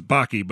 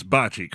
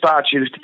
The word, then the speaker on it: bocce: I always I always mispronounce it as Baki, but it's Bachi, correct? – It is Bachi. To the best of my knowledge, I'm I'm not Italian. I'm just kind of uh, a messenger and a and a and a carrier of the sport. But yes, that's the pronunciation I've always heard.